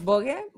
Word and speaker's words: Woge: Ah, estoy en todos lados, así como Santos Bogue. Bogue se escribe W-O-G-E y bueno, en plataformas, en redes sociales.